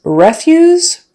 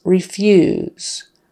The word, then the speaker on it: refuse: Refuse is the noun, meaning garbage or trash. The refuse was not disposed of properly. Refuse. Stress on the first syllable and the vowel is eh, short e. Versus refuse, long e sound, refuse, refuse. I refuse to do this again. Refuse. Stress on the second syllable and the long e, re.